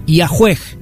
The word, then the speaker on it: Yahweh: Así, los eruditos modernos conjeturan que originalmente el tetragrama YHWH se pronunciaba Yahweh, que habría sido el origen de la palabra Yahvé.